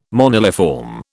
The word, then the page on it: moniliform: British English